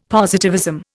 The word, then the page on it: positivism: Indian